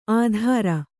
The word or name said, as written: ಆಧಾರ